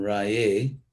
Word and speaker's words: Raye: The word Ra, R long A, and then Raye, yeah, it's like for glory, like, oh Agni, oh Lord, who is fire, lead us on the right path to glory.